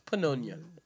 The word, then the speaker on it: pneumonia: Why do we pronounce pneumonia without the P? It's pneumonia.